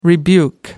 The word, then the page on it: rebuke: American English